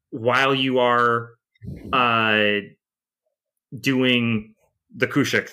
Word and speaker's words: Khusyuk: It's spelled K-H-U-S-Y-U-K. But I understand that the pronunciation is Kushuk.